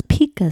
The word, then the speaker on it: Pica: Who suffered from a condition known as Pika syndrome. Hmm. If that's how you say it. Pika? Pikachu. Hmm. P-I-C-A? Pika? – Yeah.